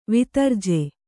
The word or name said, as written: ವಿತರ್ಜೆ